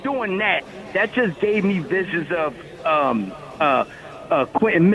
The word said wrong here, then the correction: Quinyon: Mitchell. How did I say his name right? – Quinyon Mitchell.